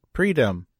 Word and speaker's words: Preetam: he said Preetam, but that's not how it's spelled. But I promise I did it right.